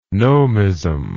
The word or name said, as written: nomism